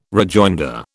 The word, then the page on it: rejoinder: British English